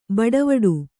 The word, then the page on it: ಬಟ್ವಾಡೆ: Kannada